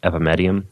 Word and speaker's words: Epimedium: There is another herb called Epimedium, and that's spelled E-P-I. And then medium, like M-E-D-I-U-M, Epimedium.